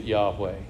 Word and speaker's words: Yahweh: To us, that looks like YHWH, and we say it Yahweh.